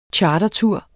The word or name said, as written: chartertur